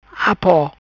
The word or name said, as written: Apo